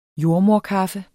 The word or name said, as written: jordemoderkaffe